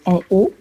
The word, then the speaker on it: Ano: Ce sont des associations créées en hommage à ma mère, qui s'appelait Antoinette Ogagna, d'où le nom en haut, D'accord. A-N.O, qui s'écrit A-N.O et qui se prononce en haut pour le jeu de mots.